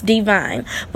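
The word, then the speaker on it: D'Vine: It's spelled D apostrophe V-I-N-E, so it's Divine, which is located in South Beach.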